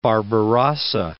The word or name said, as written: Barbarossa